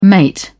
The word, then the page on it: mate: British English